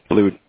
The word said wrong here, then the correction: Blut: Just long U rather than a short U.